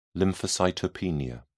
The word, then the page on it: lymphocytopenia: British English